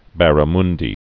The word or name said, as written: barramundi